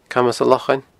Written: Camas an Lochain

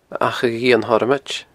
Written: Achadh Iain Thormoid

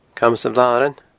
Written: Camas a' Bhlarain

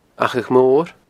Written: Achadh Mor

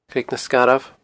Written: Creag nan Sgarbh

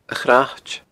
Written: Crait

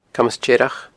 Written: Camas Tearrach

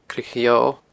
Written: Creag a' Gheodha

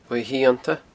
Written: uaimh shiannta

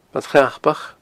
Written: Bad Chnapach

